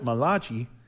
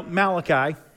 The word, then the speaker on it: Malachi: He's like, the Italian prophet Malachi. No, Malachi. So the last time he spoke was through Malachi, and he'd been silent for 400 years.